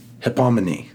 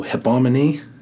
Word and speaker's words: hypomone: It's hypomene. Hypomene. It's, I think I'm saying it right. H-Y-P-O-M-O-N-E. That's how we would spell it in English.